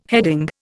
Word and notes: heading: Indian

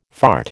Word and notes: fart: American English

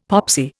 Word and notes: popsy: Indian

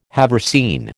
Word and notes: haversine: American English

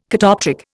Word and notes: catoptric: Indian

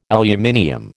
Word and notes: aluminium: American English

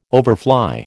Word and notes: overfly: American English

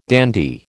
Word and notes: dandy: American English

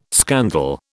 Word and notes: scandal: British English

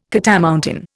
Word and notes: catamountain: Indian

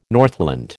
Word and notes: Northland: American English

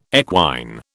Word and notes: equine: British English

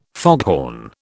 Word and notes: foghorn: British English

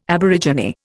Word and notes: aborigine: Indian

